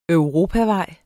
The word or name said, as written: europavej